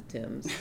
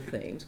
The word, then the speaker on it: Thames: And we rode eventually on the Thames, not the Thames, but the Thames. We say it properly in New London.